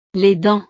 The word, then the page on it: les dents: frances